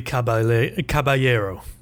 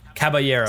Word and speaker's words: Caballero: Sarri decides to make a decision to switch him out with Calabrello. Am I saying that wrong? – Willy Caballero. – Caballero.